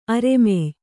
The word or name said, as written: ಅರೆಮೆಯ್